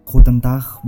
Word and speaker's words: Guten Tag: Guten Tag buat Bang Evan.